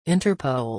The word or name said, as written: Interpol